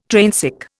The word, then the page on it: trainsick: Indian